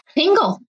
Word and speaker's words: Pingel: Pingel.